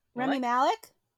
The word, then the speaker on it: Rami Malek: Rami Malek? – Rami Malek? – Malek, there you go.